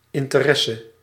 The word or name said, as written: interesse